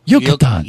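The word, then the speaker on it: Yucatán: No, say it like you're in Mexico. Yucatan.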